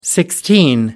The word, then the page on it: sixteen: American English